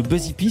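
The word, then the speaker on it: Busy P: Vous êtes DJ, connu sous le nom de Buzzy P, c'est comme ça qu'on prononce? Comment on prononce? – C'est très, votre anglais est parfait, cher Philippe.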